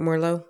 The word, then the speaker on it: Merlot: I just had like a white Merlot or whatever at that wine tasting that I was at, and I wound up buying the bottle. And I'm not if I'm saying that right, Merlot.